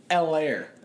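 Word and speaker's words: Helaire: L-air. The H is silent.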